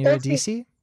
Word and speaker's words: Eurydice: but Eurydice and Orpheus and Eurydice.